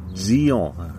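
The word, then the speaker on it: Djian: Versucht es mal mit Gion, haben sie geschrieben.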